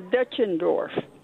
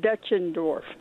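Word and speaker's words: Deutschendorf: I really called to tell you the correct pronunciation of Dutchendorf. In these parts, John Denver has relatives, and at least in these parts, I don't know what the German pronunciation is. We called the name Dutchendorf.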